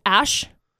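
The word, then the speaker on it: Æ: But A-E, it's a character in the Scandinavian alphabet that's pronounced as Ash.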